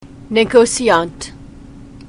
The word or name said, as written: negociant